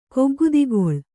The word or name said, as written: ಕೊಗ್ಗುದಿಗೊಳ್